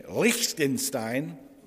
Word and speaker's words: Liechtenstein: The Republic of Congo, Poland, Republic of Moldova, Spain, Portugal, Greece, Ukraine, Germany, Croatia, Cyprus, Finland, Bolivia, Cabo Verde, South Sudan, Kenya, I'm going to use my German little pronunciation, Liechtenstein,